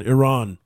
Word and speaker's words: Iran: Is it Iran? I, I've been calling it Iran. Iran. That's what it's called. Iran. That's the uh, the uh, uh, uh, proper pronunciation. Iran.